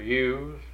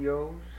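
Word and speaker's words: ewe: Or ewes. – Ever hear them called yos? – Well, that's an abbreviation. It's spelled Y-E-W, Y-E-W, mm-hmm.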